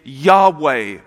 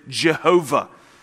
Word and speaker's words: Yahweh: You've heard the name Yahweh pronounced. That's the best as we can determine how you would pronounce God's name here. Sometimes you'll see older English translations translated Jehovah. But either way, this is the name of God that is translated by L-O-R-D in all capitals anytime you see it in the Old Testament.